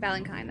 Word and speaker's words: Balanchine: Valentine. Yes. Balanchine, that's how you say it. – Is it? Okay. – That's how they say it at the ballet place.